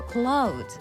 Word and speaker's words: clothes: ここでちょっと英語の豆知識なんですけれどもね、この clothes 服の発音なんですけど、 clothes、面白いことに clothes 閉めるとほぼ同じような発音なんですね。